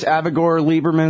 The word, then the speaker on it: Avigdor Lieberman: Now, who's this Avigdor Lieberman? Is that how you say it? – Yeah.